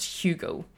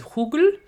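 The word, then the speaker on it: Hügel: Have certain regrets about that now, just because not taking the last name necessarily, but his name is my last name now is pronounced Hugel, but the German spelling is H-U with a macron G-E-L, which is Hugel.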